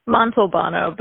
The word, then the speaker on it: Montalbano: Montalbano, but you're close.